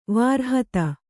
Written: ವಿರಹಿತ

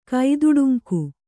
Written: ಕೈದುಡುಂಕು

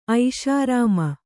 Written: ಐಷಾರಾಮ

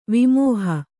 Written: ವಿಮೋಹಿ

